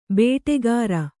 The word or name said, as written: ಬೇಟಕಾರ